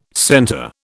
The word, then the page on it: center: British English